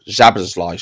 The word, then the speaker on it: Szoboszlai: Or Szoboszlai, should I say. That's the correct pronunciation of that one.